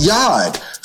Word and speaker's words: Yod: It's pronounced Yod. So instead of, in Spanish, Jota, in Greek, Iota, in Hebrew, Yod, da, Yodota, Yod. So now, this is called a Jot. The Jot is the Hebrew letter Yod, which is the smallest letter in the Hebrew alphabet.